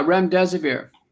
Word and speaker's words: remdesivir: I believe it was remdesivir. Am I saying that correctly? – Yeah, remdesivir.